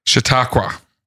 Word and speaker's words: Chautauqua: Chautauqua.